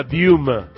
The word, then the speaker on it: Dilma: Dilma Diuma. Diuma. Diuma Rousseffi. La L es como una U en el idioma brasileño, no portugués, estrictamente portugués.